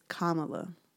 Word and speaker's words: Kamala: Akivian sent me a DM and said we were saying her name wrong. It's Kamala. Like comma, like a comma.